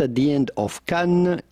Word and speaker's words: Cannes: It's silent. It's a silent S. Mm-hmm. Cannes Film Festival. Oh, excuse me. The Cannes Film Festival.